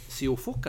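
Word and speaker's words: Siófok: Og han har faktisk nå, etter veldig mange år i Larvik, bestemt seg for å reise ut da til ungarske Siofok. Er det sånn du uttaler det? – Ja.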